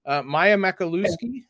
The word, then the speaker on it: Maya Makalusky: They also landed Teri Moren and her coaching staff an in-state recruit, Maya Makaluski, I believe is how you pronounce it, from Hamilton Southeastern, a 2025 recruit.